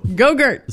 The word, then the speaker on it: Go-Gurt: Go-gurt. – Stop. Don't mess with Papa Fett. It's Go-Gurt. Oh, geez-a-loo. I've been saying this wrong for like 3 days now.